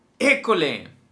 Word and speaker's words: école: Entonces esta palabra école lleva acento en la primer E.